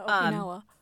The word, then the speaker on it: Okinawa: He was coming from Okinawa, Japan. – Oh. – I hope I said that correctly. – Yeah, Okinawa.